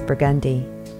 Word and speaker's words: Burgundy: Burgundy is Burgundy.